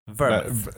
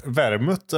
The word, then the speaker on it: vermut: Säger man vermut eller säger man vermouth? – Vermut är väl korrekt, tror jag. – Vermut, ja. – 10 centiliter vermut.